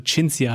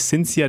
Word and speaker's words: Cinzia: Wir hatten ja, ich habe das so eine Namenslehre von dir immer zwischen in den Pausen bekommen, dass du Cinzia heißt und nicht Cinzia.